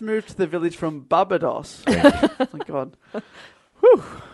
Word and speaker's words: Barbados: I thought that as well, but now I'm looking and I'm thinking, maybe is it Barbados? Is that right? Am I saying that right? – Barbados. – Oh, thank you.